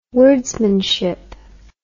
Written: wordsmanship

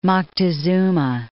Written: Moctezuma

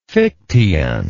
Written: fichteanism

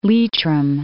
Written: Leitrim

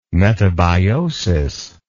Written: metabiosis

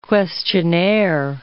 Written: questionnaire

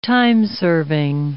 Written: timeserving

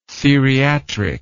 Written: theriatrics

